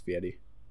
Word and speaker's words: Fieri: Fieri. I would say Fieri, but some people say it's Fieri. – Oh yeah, no, it's that's how it's actually pronounced Fieri.